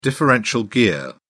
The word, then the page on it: differential gear: British English